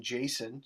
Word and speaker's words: Jason: But there was actually a point in time where I didn't like the name Jason because it's spelled Jason, but you say it sin, not son, right?